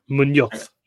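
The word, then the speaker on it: Muñoz: I've also got a Crystal Palace asset in Daniel Nunez. That's how you say it. – Munoz. – Munoz. – There's no E at the end of that, I don't think. – Yeah. No, it isn't. – M-U-N-O-Z. – Munoz. – Yeah, I'll give you Munoz.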